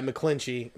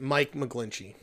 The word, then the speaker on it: McGlinchey: Yeah, McGlinchey. Mike McGlinchey.